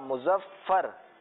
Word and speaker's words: مظفر: اچھا بہت سارے بچے کیا پڑھتے ہیں؟ مظفر پڑھتے ہیں, یا جب بہت جلدی جلدی میں ہوتے ہیں تو مظفر پڑھ لیتے ہیں. تو ایسا نہیں ہے. یہ کس کا, اس کا تلفظ کیا ہے؟ – مظفر. – جی ہاں مظفر. مظفر اس کا تلفظ ہے.